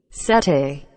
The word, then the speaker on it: settee: SETI. S-E-T-T-E-E. SETI.